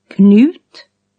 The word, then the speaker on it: Knut: KN at the beginning of a word is K plus N, unlike English. – Knut, knivar, knä, knyta, knott.